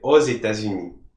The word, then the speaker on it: aux États-Unis: then you pronounce the X as a Z. For example, the United States is les États-Unis, right? États-Unis. So if you were in the United States, you would say aux États-Unis, okay?